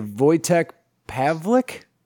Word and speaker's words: Wojtek Pawlik: I'm going to say Wojtek Pawlik? – Yeah.